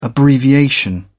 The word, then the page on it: abbreviation: British English